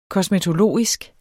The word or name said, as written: kosmetologisk